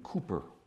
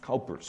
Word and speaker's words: Cowper: Now, William Cowper, often wrongly pronounced Cowper, C-O-W-P-E-R, William Cowper, great, great hymn writer and regarded, incidentally, as one of the greatest poets of the 1700s.